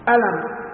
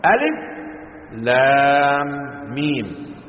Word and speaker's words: الم: فأما من الأمي الذي لم يخالط الكتاب حتى النطق، حتى النطق، أنت ألف لام مين، إذا كتبت خطا ورسمت كيف تقرأ؟ ألم ألم مش كده ولا؟ نعم. بخلاف ما هو مقصود منها لا خطا ولا مضارسة، ألف لام ميم، ولم تقرأ هكذا ألم.